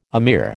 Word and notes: emir: American English

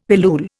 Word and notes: pilule: Indian

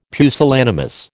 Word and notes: pusillanimous: American English

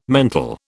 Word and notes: mental: British English

